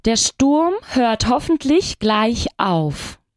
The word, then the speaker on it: hoffentlich: Der Lärm hört hoffentlich bald auf. – Esperemos que la lluvia cese pronto. – Der Regen hört hoffentlich bald auf. A ver, la pronunciación. Hoffentlich. Dilo tú. – Hoffentlich. – Muy bien.